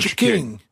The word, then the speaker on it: Cha-King: Cha-king. – It's the cha-king. – Yeah. Is it like a cash register, like cha-ching? – Yeah, you could say it like that, exactly.